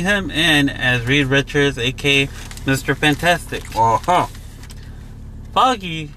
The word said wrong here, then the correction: Feige: I think I'm pronouncing it right. Kevin Foggy? – Foggy. – Foggy. – Like Fi. – Feige. – Feige. – Feige, yep. Okay.